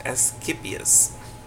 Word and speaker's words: Asclepius: Okay, it's pronounced as Asclepius,